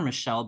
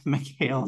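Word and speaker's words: Michaele: The casting for DC was so bad, though, except for I also call her Michelle, but I know that's not actually how you pronounce it. Michelle Salahi. – Mikhail Salahi.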